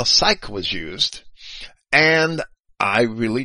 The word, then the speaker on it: sych: Nope, it's just Shin Yod, and then the Heth character, which is the hard C-H. So that would be psych, is how I would transliterate and pronounce that. S-Y-C-H, psych.